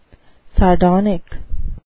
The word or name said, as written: sardonic